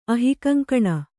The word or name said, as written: ಅಹಿಕಂಕಣ